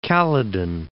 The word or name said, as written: Caledon